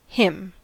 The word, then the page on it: him: American English